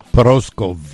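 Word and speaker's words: Prószków: Proskow, 1921, un día como hoy. Me dice si lo pronuncié bien o no, que sabe polaco. Adelante. – Proskow. – Ah, casi, casi me acerco.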